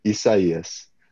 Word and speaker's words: Isaias: So, if all plays out, we will see Hurricane Isaias in 2026. So, we were joking. We're going to have a little bit of PTSD in 2026, trying to remember how to say. – Isaias.